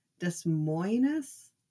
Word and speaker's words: Des Moines: Des Moines. Is that how it is? Yeah, but it's pronounced as "moine." Okay, there um, we go.